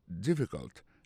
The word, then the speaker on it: difficult: Is it difficult? Difficult là, ça veut dire difficile. Lié plus comme ça, D-I-F-F-I-C-U-L-T. Difficult. N'a pas remarqué que l'accent tombé sur le premier syllabe là.